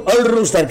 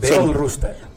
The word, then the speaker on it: Old Rooster: Bueno, All Rooster, creo que lo he dicho bien, ¿no? – Sí, así es. – A ver, acercad bien al micro, ¿eh? – All Rooster.